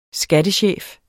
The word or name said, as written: skattechef